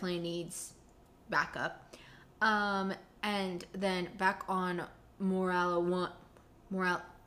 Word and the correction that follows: Morlana: How did I say it before? I can't remember. Morlana. Morlana won.